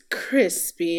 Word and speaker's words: Chrispy: And my name is Crispy, and that's spelled C-H-R-I-S-P-Y, also known as Lando Cal Crispy, and also known as Crispy Vandross, and Devastating and Dastardly.